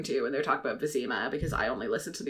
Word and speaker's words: Vizima: And then the audiobook actually gets worse, because the audiobook narrator pronounces it Vachim. – Why? There is no way. Yeah. There is no way in which you could pronounce a Z as a.